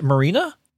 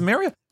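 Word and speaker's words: MariaDB: Is it Marina? – I don't know. I can't pronounce. – Oh no, words. – There is no N. It is. Yeah. It's Maria.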